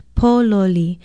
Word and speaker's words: pololi: It is spelled P-O-L-O-L-I, and the first syllable is stressed, pololi.